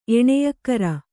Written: ಎಣೆಯಕ್ಕರ

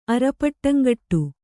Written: ಅರಪಟ್ಟಂಗಟ್ಟು

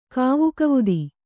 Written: ಕಾವುಕವುದಿ

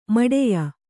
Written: ಮೌಢ್ಯ

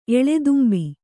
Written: ಎಳದುಂಬಿ

